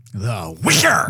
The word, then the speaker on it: Witcher: I love me some Witcher. – But you're not saying it correctly. – The Witcher! – There you go!